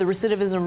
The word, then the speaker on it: recidivism: I can't believe the recidivism rate is. – We both said it right.